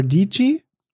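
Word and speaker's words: Dietschy: kenne jetzt ähm, Sarah Dici. Rhymes with Peachy.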